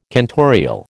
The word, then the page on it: cantorial: American English